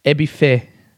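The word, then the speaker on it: Ebiphé: E-B-I-P-H-E, and the last E has an accent. Ebife.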